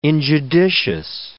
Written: injudicious

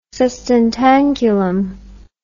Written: sustentaculum

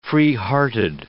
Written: freehearted

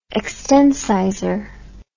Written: extencisor

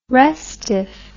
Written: restiff

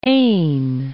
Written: Aisne

